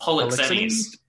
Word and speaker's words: Polixenes: How's his name pronounced? – Polixenes. Polixenes, okay.